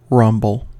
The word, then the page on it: rumble: American English